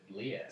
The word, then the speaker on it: Liev: Kiev, but Liev.